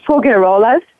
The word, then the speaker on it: Faugerolas: My name is Faugerolas, just F-A-U-G-E-R-O-L-A-S.